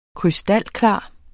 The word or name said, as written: krystalklar